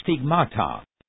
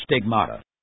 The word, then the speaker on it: stigmata: The Greek word is pronounced stigmata. The English word is pronounced stigmata.